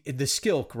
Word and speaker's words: Skilk: And before I forget this question, because it popped into my head while we were chatting, the Skilk, right? Like this, that's how I pronounce it.